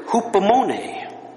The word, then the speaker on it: hupomone: That comes from the Greek word hupomone.